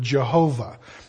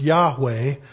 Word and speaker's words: Yahweh: Now, in some places, a Y is pronounced like a J, and a W is pronounced like a V, and so we came up with this word Jehovah, which I guess is as good a guess as anything. I kind of lean more towards Yahweh,